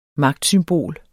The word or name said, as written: magtsymbol